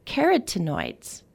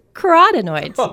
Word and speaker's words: carotenoids: And I'm so happy that I did, because I have always thought the way this word is pronounced was carotenoids. – Yeah, it's, I probably would have said it like that. – Turns out it's carotenoids.